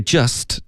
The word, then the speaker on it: just: 好，这句话开头 you've just，这个 just 的 t 的音呢，我们就彻底的省略，这个字太常见了。